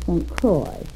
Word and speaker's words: St. Croix: Well, when I went down there, I went over to the island of St. Croix. And that is the way they pronounce it. – St. Croix, C-R-O-I-A.